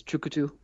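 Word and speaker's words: tchoukoutou: You know, shorthand folks over there just call it chuk, but the whole word is chukutu.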